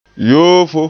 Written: yoofo

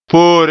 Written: poore

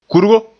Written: kurgo

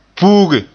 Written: puuge

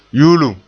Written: yuulum

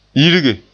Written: yirge

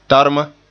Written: tarma

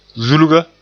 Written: zulga

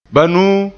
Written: banuu

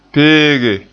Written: peege

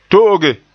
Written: to'oge

